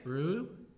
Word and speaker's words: Roux: How would you pronounce R-O-U-X? – I probably wouldn't. – All right, Rau? – Sure. – Roux? – Yeah. – Rue? – Yeah, that Rocks. Last one. That last one.